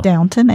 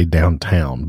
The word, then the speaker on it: Downton: We just started rewatching Downton Abbey. – I was trying to say it right. I want to say Downtown, but it's Downton.